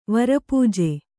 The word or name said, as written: ವರಪೂಜೆ